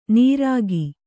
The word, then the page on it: ನೀರಾಗಿ: nīrāgi